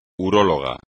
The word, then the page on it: urólogo: espagnol